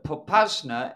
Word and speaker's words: Popasna: And what has happened is out from the city of Popasna, I think I'm pronouncing it correctly. – Close enough. – We have a Russian speaker with us. – yes. Popasna, and they're breaking out toward the west and threatening to cut off.